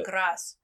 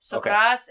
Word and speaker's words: Graz: Graz is like, the only reason why I know how to pronounce that correctly is because I had a customer the other day that was from Germany, and they were telling me.